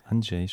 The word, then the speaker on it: Andrzej: Andrzej? – Andrzej, spricht man das aus? Ja.